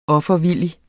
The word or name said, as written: offervillig